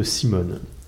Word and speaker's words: CMON: Et pour que ça fonctionne avec le thème des zombies, je vais vous parler de Simone. Alors comme on l'appelle en France, parce que ça fait un jeu de mots qui est rigolo. Mais en fait, ça s'écrit C-M-O-N pour Cool Mini or Not.